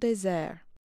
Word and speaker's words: désert: He named the island, in French, Île des Monts Déserts, or Island of Desert Mountains. Today, locals still say the word desert, similar to its French pronunciation, désert.